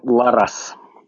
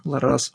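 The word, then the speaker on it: Larraz: Как его фамилия читается? – Пепе Ларас. – Лар... Ларрас, да? – Ларрас. Окей, okay, anyway.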